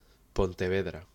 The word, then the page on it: Pontevedra: Spanish